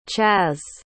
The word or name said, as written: chas